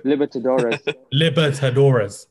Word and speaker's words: Libertadores: Libertadores.